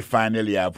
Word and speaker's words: finally: Alors, t'aimes plus finally pour nous. Finally, c'est F-I-N-A-L-L-Y. Finally. T'as des prononciations, oui? Finally. Accent tombé sur premier syllabe là. Finally. F-I-N-A-L-L-Y.